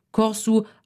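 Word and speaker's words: Kòrsou: Die Aussprache ist dementsprechend unterschiedlich. Corsu auf Papiamentu, Curaçao auf Spanisch und Portugiesisch, Curaçao auf Niederländisch.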